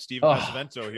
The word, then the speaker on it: Pesavento: Stephen Pesavento here in the house.